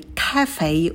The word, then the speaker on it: cafe: На к мы добавляем придыхание, потом звук лягушка э. Ка, и в конце у нас дифтонг эй, фей, фей, кафе, ударение на первый слог.